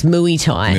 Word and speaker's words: Muay Thai: I told you that's not how you pronounce it. – What do you say? Muay Thai? – It's Muay Thai. – Muay Thai. That's not how. No, I'm going there and tell your friends. – Yeah. – Say, hey, I'm here for Muay Thai. – No. – But you got to pronounce it like this. Muay.